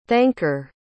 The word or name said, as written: thanker